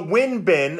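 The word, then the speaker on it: Won-Bin: Where does Juan Bin fit into the Dirty 35? So Win Bin, W-O-N, he actually pronounces it Win Bin Cho.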